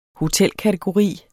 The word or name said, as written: hotelkategori